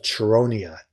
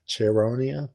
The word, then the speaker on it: Chaeronea: I love that he didn't give me a pronunciation for this, but it's the Battle of Chaeronea. Chaeronea? – Ah, well, there you go.